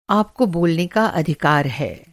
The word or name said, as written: अधिकार